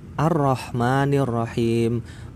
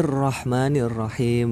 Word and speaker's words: Ar-Rahmanir Rahim: Kenapa kita bisa tahu bacanya Ar-Rahim bukan eh Ar-Rahmanir Rahim bukan uh, eh, Ir-Rahmanir Rahim bukan Ur-Rahmanur Rahim Ur-Rahmanir Rahim gitu.